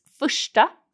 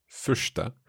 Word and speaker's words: första: So first is första. – Första. Is that an RS in there? – Yes. – Okay. Första. – Good pronunciation.